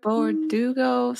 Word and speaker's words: Bardugo: Leah Bardugo, something like that.